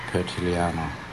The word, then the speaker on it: Pertiliano: There were students that came through Broome, Filipino students, and I asking them how to pronounce my name properly, The Filipino Way, but which at that time I learned that it was Pertiliano.